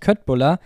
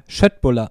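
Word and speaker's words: Köttbullar: Und genau, jeder sagt nämlich Köttbuller, aber eigentlich wird es richtig ausgesprochen Schöttbuller.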